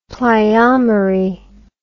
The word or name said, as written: pleiomery